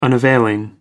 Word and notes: unavailing: American English

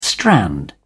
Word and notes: strand: British English

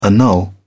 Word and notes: annul: British English

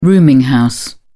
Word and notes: rooming house: British English

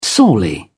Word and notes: sorely: British English

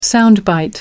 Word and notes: sound bite: British English

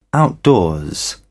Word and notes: outdoors: British English

